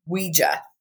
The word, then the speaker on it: Ouija: The first one is we, French, and then I believe it's the Dutch, which is the ja. So we Ouija. Okay. Yeah. J A ja is also yes in German, but it's pronounced ja. – Right, exactly. So Ouija.